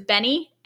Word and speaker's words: BENEE: B-E-N-E-E. – Renee. – I have said Benet as well, but then I heard it was Benny, and I got very confused, I just stopped talking.